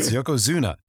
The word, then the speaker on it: Yokozuna: Of course it's Yokozuma. They can't even say his name right. – Yeah. Do you think he turns to him, it's Zuna. – That's what I said. Yokozuma. – Say whatever you want, Fuji. – Yes, it's Yokozuna.